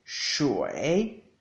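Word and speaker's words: shui (水): Agua en chino se dice shui, shui, shui, con un tercer tono en la i, shui, shui.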